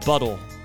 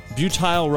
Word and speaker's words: butyl: Random fact number three, gum is made of butyl rubber, or it's spelled like buttle, but it's not. It's butyl rubber.